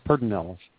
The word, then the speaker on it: Pedernales: Pedernales. – Pedernales, thank you. Pedernales, yeah. – It's not spelled that way. It's spelled completely different, but, yeah, you would think there's an R before that, but no.